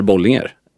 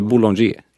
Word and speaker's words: Bollinger: Medan Bollinger är Bollinger. Man säger inte Boulanger i Sverige.